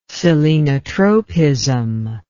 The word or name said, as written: selenotropism